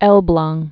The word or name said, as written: Elblag